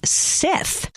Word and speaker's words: scythe: Skith or Scythe. I don't know how it's pronounced. We'll have to look it up. S-C-Y-T-H-E. – Look up how it's pronounced. Doesn't it say it right there? – Yeah, I'll look it up. – What is it? – Way to go. – Skith? – I mean, I think you said this. It is actually pronounced Sith.